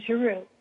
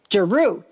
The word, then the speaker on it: Giroux: Straus & Giroux. Did I pronounce that right? – It is Farrar, Straus & Giroux. Yeah. – Giroux. Okay.